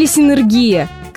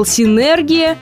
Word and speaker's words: синергия: Но все словари и ударения оказались непреклонны, а верным вариантом оказалась синергия. Синергия и только. Родом синергия из Греции. Этим и объясняется непривычное для нас ударение.